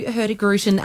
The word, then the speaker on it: Hurtigruten: Tell us a bit about who Hurtigruten are and where do you cruise? – Yeah, sure.